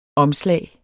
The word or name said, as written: omslag